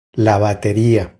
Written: La bateria

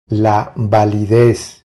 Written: La validez

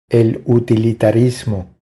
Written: El utilitarismo

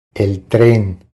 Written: El tren

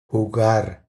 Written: Jugar

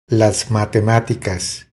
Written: Las matematicas

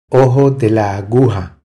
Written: Ojo de la aguja

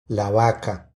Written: la vaca

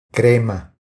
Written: Crema

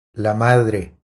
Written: La madre

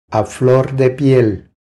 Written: A flor de piel

